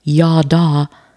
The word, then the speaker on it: yadah: So the root word for it, yad, Y-A-D, literally means to use the hand. And then if we look at yada, Y-A-H-D-A-H pronunciation, spelled Y-A-D-A-H, yada.